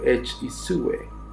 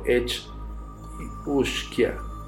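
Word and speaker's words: each-uisge: Estos caballos de aguas mutantes, pertenecientes al folclore de Escocia e Irlanda, son conocidos como Kelpie o Edge Isue, pronunciado Edge Ushkia,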